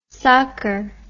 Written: socker

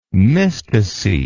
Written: mysticete

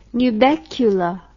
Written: nubecula

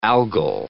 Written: algal

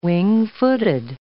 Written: wing-footed

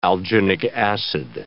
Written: alginic acid

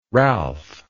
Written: ralph

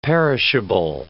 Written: perishable

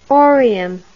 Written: orium

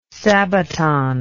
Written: sabaton

